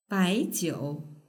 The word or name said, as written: baijiu